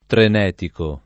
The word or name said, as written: trenetico